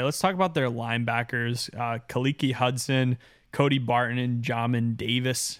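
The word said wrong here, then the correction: Khaleke Hudson: Kalik Hudson. Say it right, bro.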